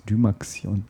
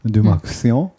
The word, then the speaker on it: Dymaxion: So, und es gibt dann so drei typische Modelle, die äh, Uberman, Dymaxion oder wie spricht man das aus? Dymaxion. – Dymaxion. – Hm. Genau Dymaxion so.